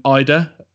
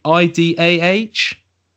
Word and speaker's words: Idah: So people look out for Adam Idah. I'm guessing that's how you pronounce his name. I D A H.